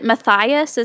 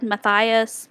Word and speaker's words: Matthias: Is it Matthias? Is that how it's said? Matthias? Matthias? – I think it's Matthias. It's a made-up world. It's not real. So, that is a real name, though. – That is a real name, but you don't know how they pronounce things there. It's not real. – The internet told me it was Matthias.